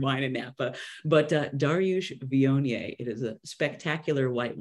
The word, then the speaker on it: Viognier: So I never heard of the white wine Viognier before. It looks like Wagner, like it's spelled weird, but it's a really nice white wine.